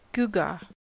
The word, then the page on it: gewgaw: American English